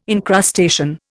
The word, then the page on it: encrustation: Indian